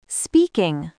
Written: speaking

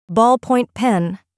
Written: ballpoint pen

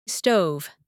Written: stove

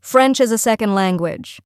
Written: French as a Second Language

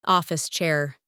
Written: office chair

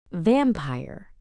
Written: vampire

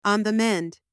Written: on the mend

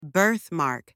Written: birthmark

